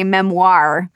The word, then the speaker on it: memoir: I realized I've been saying the French version and that you actually say memoir my whole life.